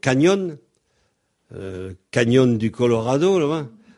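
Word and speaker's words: cañón: Alors, on écrit C-A-N tilde O-N. Et on pense canyon. Mais quelquefois, en français, on écrit C-A-N-Y-O-N. Autrement dit, le N avec un tilde dessus, un tilde, se prononce nye, comme en français, le G-N.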